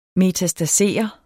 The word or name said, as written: metastasere